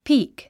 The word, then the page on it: peek: píːk; ピーク